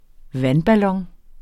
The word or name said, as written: vandballon